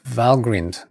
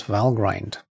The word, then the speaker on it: Valgrind: Valgrind, pronounced Valgrind, not Valgrind, memcheck tool.